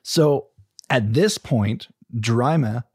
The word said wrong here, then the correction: Jurimi: or Jurimi, sorry, Jurimi is how you say his name,